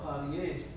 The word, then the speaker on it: polyandry: What is polyandry.